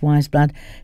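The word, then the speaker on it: Weyes Blood: And before that, we offered up Wise Blood, or Wise Blood actually, it's pronounced Wise Blood. It's written by, like Eyes Blood, both with a W at the beginning, and I always call it Ways, but it is Wise.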